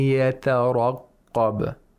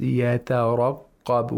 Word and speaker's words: يترقب: When we want to pronounce يَتَرَقَّبَ, we pronounce it with caution. We pronounce it cautiously. Why? Because we have two letters of tarqiq, one letter of ra with tafkhim, and then a qaf with tashdeed, with shatta and tafkhim. يترقب. We have to, you know, we have to pronounce it deliberately with caution.